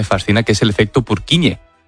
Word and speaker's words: Purkinje: Es que se escribe, en español sería Purkinje. – Purkinje. – Pero Purkinje es en checo, que es por porque es el apellido del descubridor,